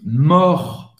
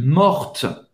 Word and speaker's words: mort: Vous ne vai prononcer. prononcer T. Agora, para ma mulher, je suis morte. Je suis morte. Plein Molière, vous vai falar o T. Et T français, mais francês, mas acaba no T. Donc, vous vai pronunciar o T no final.